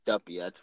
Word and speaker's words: Duppy: Duppy, that's how you say it.